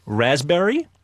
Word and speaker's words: raspberry: K-N-E-E-L, Neil. – Raspberry, answer. – Silent letters. – Yes, silent letters. Raspberry is... – the silent S. – P. – Well, P. Sorry.